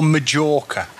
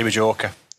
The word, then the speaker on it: Majorca: Do you mean Mallorca or Majorca? – Oh, nobody says Majorca, do they? – Yeah, what? Some people say Majorca.